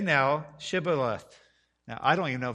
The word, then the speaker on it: Shibboleth: And he said Shibboleth, for he could not frame to pronounce it right. So you see that the H is missing there.